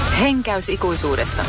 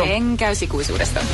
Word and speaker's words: Henkäys ikuisuudesta: Henkäys ikuisuudesta, the Christmas album. – Ecco. – Henkäys ikuisuudesta.